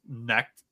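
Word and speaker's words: Knecht: See, originally I thought it's pronounced Neck too. I mean, that's just the KN connect, pronunciation. Neck. A lot of people are saying Connect.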